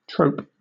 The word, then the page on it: trope: British English